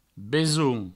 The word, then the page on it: besonh: Provençal; besoun